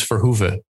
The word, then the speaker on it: Verhoeve: My last name is Verhoeve.